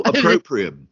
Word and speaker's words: opprobrium: There is an argument that Laura Koonsberg's first show, Laura Koonsberg has attracted lots of opprium. How's it pronounced? Opprobrium? Lots of opposition. – Oh, opprobrium. Yeah.